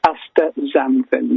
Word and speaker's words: Astaxanthin: X is a cold, like a Z, actually. Astaxanthin, yeah.